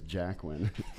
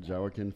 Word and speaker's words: Joaquin: I used to think it was Jacqueline. Jawakin Phoenix.